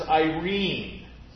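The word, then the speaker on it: eirene: In fact, that's the way it's pronounced in the Greek. It is Irene.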